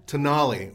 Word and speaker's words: Tonale: Alfa Romeo Tonali, or as you said, toenail. – Well, how do you spell it? – T-O-N... A-L-I. – I? Isn't it an E? – I put an I there, so I would pronounce it right. – I think it's T-O-N. Yeah, it is. T-O-N-A-L-E. – That's in the Italian.